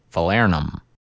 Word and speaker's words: falernum: And the next one is falernum.